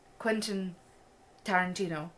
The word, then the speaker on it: Tarantino: It's Quentin Tarantino.